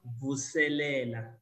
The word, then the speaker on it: Vuselela: It's pronounced Vusilele.